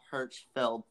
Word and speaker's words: Hirschfeld: So anyway, Moulin Rouge, the stage musical, opened on Broadway July 25th, 2019 at the Al Hirschfeld Theater.